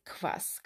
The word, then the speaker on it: kvass: It's not actually an ingredient, it's like a meal, and it's spelled KVASS. Yes, as in ass.